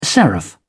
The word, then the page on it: seraph: British English